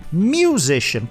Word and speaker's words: musician: Musician, como a gente fala no dia a dia mesmo.